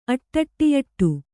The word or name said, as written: ಅಟ್ಟಟ್ಟಿಯಟ್ಟು